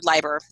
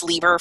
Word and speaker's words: Leiber: or Leiber. And I'm sorry, I have called him Fritz Leiber for my whole life until a month ago in his autobiography, he says it's pronounced Leiber.